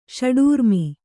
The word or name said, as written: ಷಡೂರ್ಮಿ